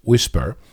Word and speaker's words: WSPR: First of all, WSPR, pronounced whisper, is a way of encoding information and transmitting it across the spectrum.